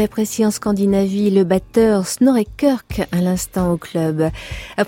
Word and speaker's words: Snorri Kirk: Alors, Beat, c'est le nom du nouvel opus du batteur norvégien Snorri Kirk, que je prononce magnifiquement. – À la norvégienne.